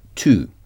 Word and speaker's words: to: Le mot to s'écrit T-O. Ça se prononce de la même façon que too late, trop. Mais dans too late, to s'écrit comment? – T-O-O. – Ben oui, bravo. T-O-O.